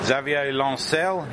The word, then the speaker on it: Xavier Lancel: Okay, I'm here at Angoulême with Xavier Lancel. Is that a good pronunciation? – Yes, that's perfect.